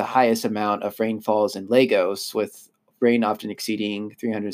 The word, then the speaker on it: Lagos: And it's basically, as much of Lagos is, or Lagos, excuse me, is how you pronounce it.